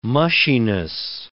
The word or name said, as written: mushiness